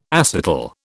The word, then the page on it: acetal: British English